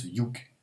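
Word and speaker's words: Youg (юг): Donc ça se prononce Youg.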